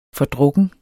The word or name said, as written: FORDRUKNE